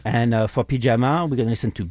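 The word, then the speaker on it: Pijama: But it's not spelled like a pyjama in French. It's P-I-J-A-M-A. And it's the letters are separated. – Interesting. – Yeah. P like pie and ja like ja in German and ma like...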